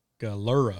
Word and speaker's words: Galera: Galera? Yeah. – So three syllables. Galera.